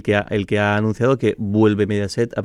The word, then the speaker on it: Mediaset: No, no, no, es Mediaset.